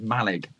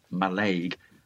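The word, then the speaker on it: Mallaig: And that is Malig, not Malaig. As most people south of the border, and actually many people up here in Scotland pronounce it Malay. It is Malig.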